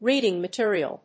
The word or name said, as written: reading material